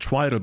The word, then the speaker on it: Twiddle: Is that how it's pronounced? Everybody in Germany calls it Twiddle, but obviously you're the native speaker. – It is definitely Twiddle. – Okay. Yeah. See, Germany, you got it all wrong.